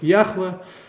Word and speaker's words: Яхве: Оно произносится как Яхве, а в научной литературе, в русской литературе с 19 века начиная, закрепилось произношение Иегова.